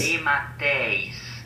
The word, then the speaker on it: DeMatteis: DeMatteis. – That's accurate. – DeMatteis. – There he is. He's probably going to write us a letter if we have it wrong. – John Mark DeMatteis.